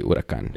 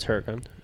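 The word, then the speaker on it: Huracán: Huracan? Oh, you say Huracan. – Yeah, it's Huracan.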